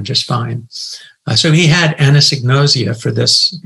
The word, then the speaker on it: anosognosia: In fact, the research shows that we're dealing with a symptom called anosognosia. And if you want to pronounce it, here's what it looks like. Here's what it sounds like.